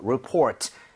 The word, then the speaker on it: report: report 이렇게 발음할 때 레가 아니라 르, report.